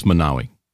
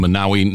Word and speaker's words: Monowi: M O N O W I. Yeah. How do you say that? Uh, I think it's Manawi. Mm-hmm. Manawi, Nebraska.